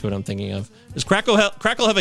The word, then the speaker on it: Krackel: K-R-A-C-K-E-L. Crackle.